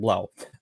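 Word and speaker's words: 3LAU: А, Парадиг и Founders Fund Питера Тиля инвестировали в NFT-платформу DJ Blau то есть есть такой товарищ Джастин Блау, а, у него ник Трилау пишется, но это как Блау.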